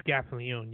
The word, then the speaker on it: Gaffalione: Yeah, it is Gaffalione. You got that right.